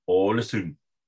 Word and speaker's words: Ålesund: Und das U wird wie ein Ü ausgesprochen und das D am Ende wird nicht gesprochen. Das heißt, der Deutsche sagt Ålesund, aber korrekt heißt es Ålesund.